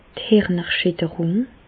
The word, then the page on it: d' Hirnerschitterùng: Alsatian; Bas-Rhin; Reichshoffen